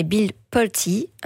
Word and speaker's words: Bill Pulte: C'est pas Bill Poole, mais Bill Pulte, un riche homme d'affaires, ami de Trump et désormais chef du renseignement américain.